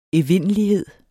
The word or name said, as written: evindelighed